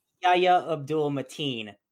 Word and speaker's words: Yahya Abdul-Mateen: Yahya Abdul-Mateen.